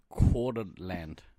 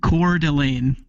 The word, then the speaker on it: Coeur d'Alene: Coeur d'Alene. – Coeur d'Alene. – Coeur d'Alene. – Think like French pronunciation.